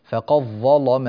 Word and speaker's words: فقد ظلم: تعالوا إلى الآية الأولى، نعود إلى الآية الأولى في قوله تعالى فقد ظلم نفسه، فقد ظلم نفسه، هنا الدال تدغم في الظاء فقض، ولا يعود للدال بقية ما دمنا في حالة الوصل، أما إذا وقفنا فنقول فقد فقد وتظهر الدال، أما إذا وصلنا فتدغم الدال وينطق بها بظاء يعني بإبدالها ظاء ثم تدغم الظاء في الظاء، هكذا يتم الإدغام، فيكون النطق بظاء واحدة مشددة وتفخم اللام كما سنتعلم اليوم.